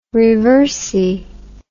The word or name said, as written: reversi